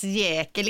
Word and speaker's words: Jäkel: måste ju uttalas Jäkel, inte jä Jäkel.